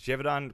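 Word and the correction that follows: Jadeveon: Clowney. I just was saying Jadavian Clowney.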